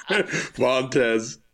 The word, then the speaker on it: Vontaze: And I just want to say for a minute, how incredible it is that you pronounce his name Vontaze. Vontaze Burfichts. – What is it? What is it? How do you pronounce it? – Vontaze. – It's just Vontaze Burfichts.